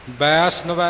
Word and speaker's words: वैष्णव: वैष्णव है, सस्से दे चरणा विच नन्ना है, सो वैष्णव है, ये पाठ का उच्चारण है जी।